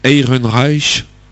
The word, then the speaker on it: Ehrenreich: Comment tu dis? – Ehrenreich. – C'est ça.